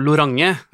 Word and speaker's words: Lorange: Vi har jo kommet frem til her at vi vil uttale det som Lorange, ja.